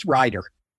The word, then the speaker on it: Rieder: You know, my whole life, people have gotten the spelling and the pronunciation of Rieder, so I'm used to it. But thank you. Yes, Rieder. But it looks like Rieder.